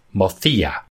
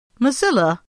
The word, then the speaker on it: Mozilla: I think you pronounced it wrong. I think it's actually pronounced Mothia, because it's Spanish. – Mozilla? – Mothia.